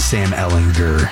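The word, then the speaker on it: Ehlinger: It is Sam Ellinger. Hard G. Not a J.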